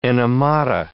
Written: enemata

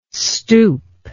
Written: stoup

